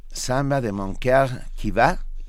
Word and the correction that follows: Samba de mon cœur qui bat: eh, el samba de mi corazón que late. – ¿Lo dije bien? – No. – No, entonces dilo tú, a ver. – Va de nuevo. – Samba de mon cœur qui bat.